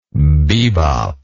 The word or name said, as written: bebopper